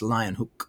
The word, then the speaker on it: Leeuwenhoek: Well, he lived at the same time in the same town down the street from Anthony van Leeuwenhoek, I think is how his name is pronounced. I have a friend who's Dutch and he said it's Leeuwenhoek. So Leeuwenhoek, was the father of microbiology, and he made microscopes and he made lenses.